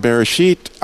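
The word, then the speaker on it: Bereshit (בראשית): If you go look in the beginning, God, well, the way that the actual, it's called Bereshit, I know that's how they say it in Hebrew, but it starts with a bet because God wanted for him place, a place where he could stretch out and be himself.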